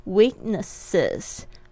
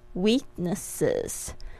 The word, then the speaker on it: weaknesses: 然后这边呢加上复数的时候，大家特别注意一下怎么念呢？ Weaknesses， Weaknesses。